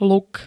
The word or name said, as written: luk